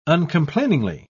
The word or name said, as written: uncomplainingly